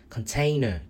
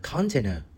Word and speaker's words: container: Và kể cả cái container nó cũng không đúng. Cái trọng âm cái nó phải nhấn ở cái container chứ không phải là container.